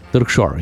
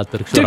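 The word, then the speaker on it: Târgșor: la penitenciarul și acum să ne hotărâm cum îl pronunțăm. Târcșor? Târcșor, yeah. – Târcșor. Da, Târcșor, a ajuns.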